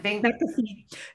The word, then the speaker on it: McAfee: Have you seen that McAfee, and I never say his name right. – McAfee.